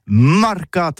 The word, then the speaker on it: marcats: Mais en Gascogne toulousaine, c'est prononcé sur les marcats avec un A.